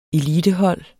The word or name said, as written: elitehold